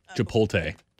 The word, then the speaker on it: Chipotle: Chipotle, they say. – Chipotle. – Oh, good Lord. There's an L in there, people.